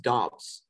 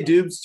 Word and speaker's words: Doubs: Also, I believe his last name is pronounced Dobbs, just so you Dobbs. – Know. – Dobbs. Okay, Dobbs. – I used to say Dubs, too, but I saw a video.